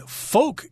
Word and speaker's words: folk: That sounds like a pretty weird game to be playing there, but we're describing these games as being folk games. Folk here, F O L K, the L is silent there, refers to people or just common people.